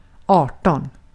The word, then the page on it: arton: /ˈɑːrˌtɔn/